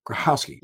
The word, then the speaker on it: Grochowski: Grochowski.